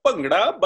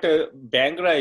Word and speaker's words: Bhangra: Correct pronunciation is Bhangra, but Bhangra is okay because I know that sound is missing.